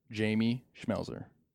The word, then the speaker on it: Jamie Schmelzer: And today, we are honored to have our first guest, the main man, Jamie Schmelzer.